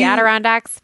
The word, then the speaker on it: Adirondack: Adirondack chairs. Did I say it right? – Yes, the Adirondacks.